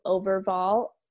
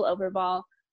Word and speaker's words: Overvoll: So it's my name at Rachel Overvoll. I'll spell that for you because it's complicated. O, V as in Victor, E-R, V as in Victor, O-L-L. So at Rachel Overvoll.